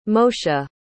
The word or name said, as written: moshe